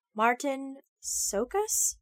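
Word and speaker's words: Csokas: Martin Sokas? – Yeah, I believe that's how you say that, yeah.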